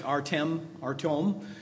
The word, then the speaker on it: Artem: That's Artyom, spelled A-R-T-E-M. Looks like Artyom is how it's pronounced, and his wife, Lisa.